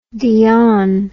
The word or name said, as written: dionne